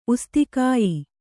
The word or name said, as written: ಉಸ್ತಿಕಾಯಿ